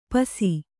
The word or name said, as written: ಪಸಿ